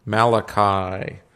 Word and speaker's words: Malachi: Malachi. So you see how to spell it up there? M-A-L-A-C-H-I, or Malachi, as they say here in Chicago. Malachi. Okay. Good? Got it? M-A-L-A...